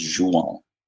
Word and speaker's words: João: Correct pronunciation, proper pronunciation in Portuguese, yeah. Anyways, is João. – Yeah. – Jou, like French for game. – Yeah. – And on, like on, but minus the N, I guess. So João.